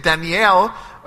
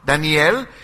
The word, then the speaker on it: Daniel: Now, young Daniel, which by the way, his name is Daniel, as we said, Daniel in Hebrew, Daniel in Hebrew. That's how it is pronounced, Daniel.